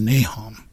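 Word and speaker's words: Nahom: So it's NHM, and the pronunciation in the Book of Mormon is Nahum, mm-hmm.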